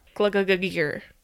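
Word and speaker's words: Gluggagægir: I'll spell it. G-L-U-G-G-A-G. The A and the E combo. G-I-R. Glug-a-g-gear.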